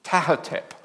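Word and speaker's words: Ptahhotep: P-T-A-H-H-O-T-E-P. I think you pronounce it Tahotep, because you don't pronounce the first P.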